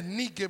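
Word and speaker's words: Nike (νίκη): Que algunos la han llevado al inglés y le dicen Nike, pero la palabra exactamente como se pronuncia es Nike, porque es una palabra griega que significa victoria.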